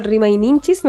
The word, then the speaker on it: Rimayninchis: en el tuyo Rima y Ninchis, no sé si lo dije bien.